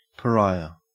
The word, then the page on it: pariah: Australian English